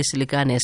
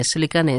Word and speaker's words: Selekane: Thank you, Billy Selekane. Selekane is spelled S-E-L-E-K-A-N-E, and that's Billy with a Y.